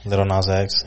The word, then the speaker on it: Lil Nas X: That's how you say it? – Yeah, Lil Nas X.